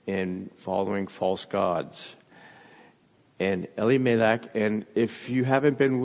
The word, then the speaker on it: Elimelech: But as I've been studying and trying to understand this from the Jewish perspective, I found that the way they pronounce his name is Elimelech, with the emphasis on the L, which stands for God, because his name means God is my king.